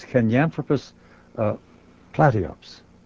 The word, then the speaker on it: Kenyanthropus platyops: Now, only a few years ago, in 1999, I believe, Maeve Leakey, who's with us tonight, found the form classified as Kenyanthropus platyops.